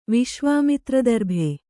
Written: ವಿಶ್ವಾಮಿತ್ರದರ್ಭೆ

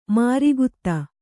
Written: ಮಾರಿಗುತ್ತ

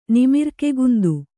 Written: ನಿಮಿರ್ಕೆಗುಂದು

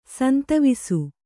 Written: ಸಂತವಿಸು

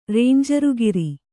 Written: ರೇಂಜರುಗಿರಿ

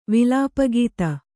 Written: ವಿಲಾಪಗೀತ

